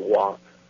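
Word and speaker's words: Wah (ו): It does, you know, like the W in Hebrew is Wah, which is the letter is pronounced Wah, mm-hmm.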